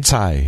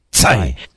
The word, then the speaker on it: Tsai: And just to throw out that URL, Michael Tsai, is that how you pronounce it? Michael Tsai? – Tsai, yeah. Tsai. T-S-A-I.